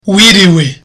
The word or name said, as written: wiriwe